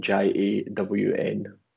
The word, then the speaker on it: Jawn: Yeah, Jawn Photo. So it's spelled J A W N.